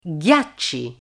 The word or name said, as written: ghiacci